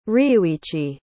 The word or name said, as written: ryuichi